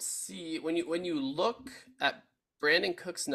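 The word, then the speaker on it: Brandin: He spells it with an I, but it's pronounced Brandon.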